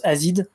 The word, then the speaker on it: AZD: En fait, j'avais écouté quand même AZD, que je découvre qu'on prononce Azid d'ailleurs, son album de 2017, je crois, et j'avais pas énormément accroché.